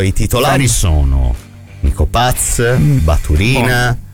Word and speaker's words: Baturina: Ma la pronuncia è Baturina, non mi fido. – Baturina, perché no, sui giocatori dei balcanici.